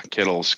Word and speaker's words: Kittle: Yeah, Kittle's good. If he's healthy. – I heard his name is actually Kit. – Have we been mispronouncing? – It's really Kittle.